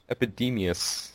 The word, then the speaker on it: Epidemius: sorry if I pronounce this wrong. Epidemius? – Yeah, I think that's pretty close. – Epidemius.